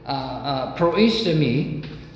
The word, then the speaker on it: Proistemi: That's the word phonetically in the Greek. Proistemi, spelled P-R-O-I-S-T-I-M-I. Okay? T-E-M-I.